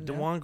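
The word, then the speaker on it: Dajuan: DeJuan, would you say? – DeJuan Gordon on the backboard as time expired.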